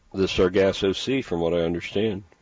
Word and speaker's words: Sargasso: It's like sawgrass something, but it's not how you say it. – Sargasso. – Sargasso? – Sargasso, yeah. Sargasso seaweed.